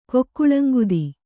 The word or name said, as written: ಕುಕ್ಕುಳಂಗುದಿ